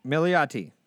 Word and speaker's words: Milioti: It is Andy Samberg and Kristen Maialato, whatever, the mother from How I Met Your Mother. – Kristen Milioti? – Milioti.